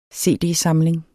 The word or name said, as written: cd-samling